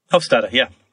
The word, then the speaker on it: Hofstadter: Hofstadter, yeah.